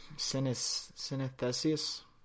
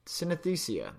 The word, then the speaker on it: synesthesia: Synesthesia. Synesthesia? No, not even close. Synesthesia. Synesthesia. There we go. Got it. Nailed that.